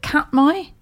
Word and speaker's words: Katmai: These are the specific pictures actually published by Katmai National Park in Alaska. Is it Katmai? K-A-T-M-A-I? – That's how I would pronounce it, but...